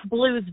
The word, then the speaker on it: Bleau: and but we do pronounce it Blue.